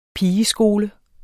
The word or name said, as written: pigeskole